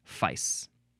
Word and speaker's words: fyce: It is spelled F-Y-C-E, and it is a variation of feist, spelled F-E-I-S-T. But do you still pronounce the T when you've got this other spelling, F-Y-C-E, that has no T at the end? I don't know, but at the very least, it looks like it is at least pronounced Fice. Next is F-Y-I, all caps, abbreviation for your information.